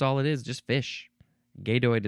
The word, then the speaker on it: gadoid: It's gadoid or gadoid. G-A-D-O-I-D.